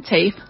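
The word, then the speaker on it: Taaffe: Se escribe bastante diferente, se escribe Ellen Taafe Swilich con Z el segundo apellido. Pero ella dice Ellen Tafe Swillick.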